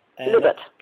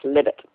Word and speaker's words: Libet: Libet. I asked him himself. I was delighted when I first met him, and we had wonderful discussions about free will and free won't and all sorts of other things, and he said his name is pronounced Libet.